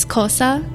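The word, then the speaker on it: Xhosa: It's called Tosa, but I think in English the pronunciation is Kosa because it's hard for people who don't.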